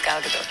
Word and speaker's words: Gal Gadot: But yeah, my name is Gal Gadot. – Gal Gadot.